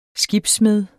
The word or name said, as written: skibssmed